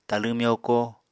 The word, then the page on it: talẃ myoko: apatani